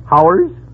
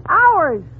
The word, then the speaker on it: hours: Now, how do you pronounce H-O-R-S-E? – H-O-R? Oh, that's easy. That's horse. – Fine. Now, how do you pronounce H-O-U-R-S? – H-O-U-R-S? Howers? – You pronounce it ours.